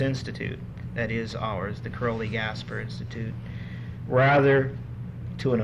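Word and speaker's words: Károli: By the way, our school, Crowley Gasper, they pronounce it Carly.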